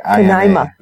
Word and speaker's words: Canaima: Next up we have is Kanima. – Kanima. – Kanima. Is that how you say it? C-A-N-A-I-M-A. – Kanima. Yeah. Yep, it's Venezuelan.